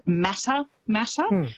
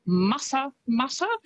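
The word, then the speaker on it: Matamata: Quite often, because of how it's spelt, people will pronounce it Mata Mata, but it's Mata Mata, which is, I think, a lovely way to say it.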